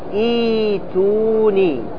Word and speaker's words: ائتوني: ما تقول ائتوني، لا تنطق بالهمزة، عندما تبتدي بها تقول ائتوني، واضح؟ تقول اي، الهمزة هذه ترجعها إلى ماذا؟ إلى الياء، ترجعها إلى الياء، فأصلها إيتي، أصلها إيش؟ إيتي، فتقلب هذه الهمزة إلى الياء، فتقول بعد ذلك إيتوني بكتاب، هذا إذا أردت الابتداء، إذا أردت الابتداء.